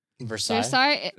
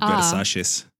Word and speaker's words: Versailles: How do you say Versailles? – Versailles? – That's how it's French. – Oh. Yeah. It's French.